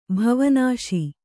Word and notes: ಬವನಾಸಿ: bavanāsi; kannada

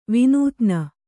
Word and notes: ವಿನೋದಿನಿ: kannada

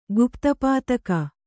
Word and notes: ಗುಪ್ತಪಾತಕ: gupta pātaka

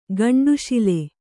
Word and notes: ಗಂಡುಶಿಲೆ: gaṇḍuśile

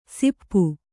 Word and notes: ಸಿಪ್ಪು: Kannada

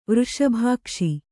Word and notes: ವೃಷಭಾಕ್ಷಿ: vřṣabhākṣi

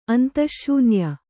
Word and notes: ಅಂತಃಶೂನ್ಯ: antahśunya

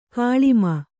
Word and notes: ಕೆಳಮೈ: Kannada

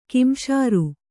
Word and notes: ಕಿಂಸಾರು: kimsāru

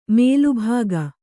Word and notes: ಮೇಲುಭಾಗ: mēlu bhāga

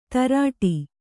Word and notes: ಧಾರಾಟ: kannada

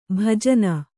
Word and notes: ಭೂಜಾನಿ: Kannada

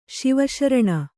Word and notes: ಶಿವಶರಣೆ: kannada

